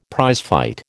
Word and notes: prizefight: American English

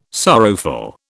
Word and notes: sorrowful: British English

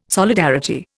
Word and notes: solidarity: Indian

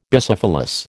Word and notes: Bicephalous: American English

